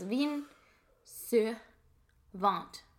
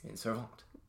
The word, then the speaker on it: Vin Sur Vingt: So according to our couple of videos that we've watched with pronunciations, it is Vin Sur Vingt. – Vingt. – Which is... – Vin Sur Vingt.